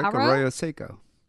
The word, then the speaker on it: Arroyo Seco: Arroyo Seco Park? – That's pretty quick, Arroyo Seco.